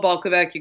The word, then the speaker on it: Balkovec: Well, if you can spell Balkovec, you can find me basically anywhere.